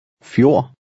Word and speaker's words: fjord: The D at the end is silent. – Fjord.